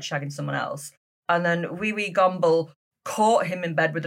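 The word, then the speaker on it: Wewe Gombel: But it's spelled W-E-W-E, so wee-wee, and then G-O-M-B-E-L, wee-wee gomble.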